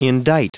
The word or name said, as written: indite